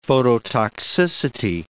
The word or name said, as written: phototoxicity